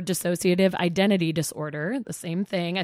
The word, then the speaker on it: dissociative: Tell me about disassociative. Did I say it right? – Or sort of dissociative. I think you might have added a syllable, but I like it. – Dissociative.